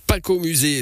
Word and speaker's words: Pakomusé: Paco Musée, ça s'écrit presque comme ça se prononce.